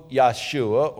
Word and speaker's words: Yahshua: The Hebrew Yahshua, or, well, we pronounce it Joshua, but in the Hebrew it's Yahshua.